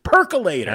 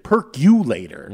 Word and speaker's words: Percolator: And it combines that with a Chicago House Legends song, Kashmir's Percolator. – I was hoping you'd mention that. – That's Curtis Jones pronounced it, Perculator, mm-hmm.